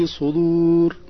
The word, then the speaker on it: الصدور: الأولى تتعلق بنطق القارئ لكلمة الصدور في الآية 19 من سورة غافر، حيث نطقها بقلب الصاد إلى سين والدال إلى طاء أو تاء. والصحيح أن الكلمة في الآية الكريمة بالصاد وبنطق الدال صريحة. يعلم خائنة الأعين وما تخفي الصدور.